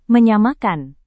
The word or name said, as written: menyamakan